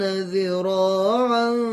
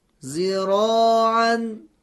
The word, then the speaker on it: ذراعا: اگر این دو کلمه با حرف زا تلفظ بشه قطعا تلفظ ما غلط هست. بشنوید به چه صورتی خواهد شد. – زرعها زراعا. – خب این تلفظ تلفظ غلط است، چون ما حرف زا رو به جای دال تلفظ کردیم،